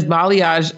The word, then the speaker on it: balayage: A lot of people say balayage and I'm like, it's balayage.